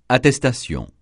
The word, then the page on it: attestation: francais